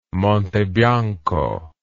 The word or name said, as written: Monte Bianco